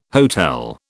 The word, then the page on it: hotel: British English